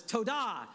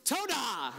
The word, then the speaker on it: Todah: It goes, an emphasis on the da. So it's Todah. That's how you say thank you. So God is like, ta-da. And then our response is Todah, right?